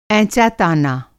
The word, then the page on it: एँचा-ताना: Hindi